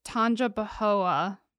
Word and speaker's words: Tangipahoa: I think it's Tangipahoa Parish in Louisiana. – That sounds right.